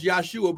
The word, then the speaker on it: Yahshua: His name would have been pronounced Yahshua because there's no J in the Hebrew language.